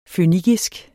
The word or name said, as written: fønikisk